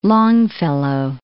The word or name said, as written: Longfellow